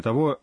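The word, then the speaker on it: «то» (とお): Кроме того, есть отличие в написании и произношении количественного числительного 10. Там нет суффикса «цу», и оно пишется и произносится «то».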